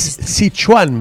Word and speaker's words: Sichuan: No es Sichuan ni Sichuan. Es Sichuan, me dijo. – Ah, Sichuan.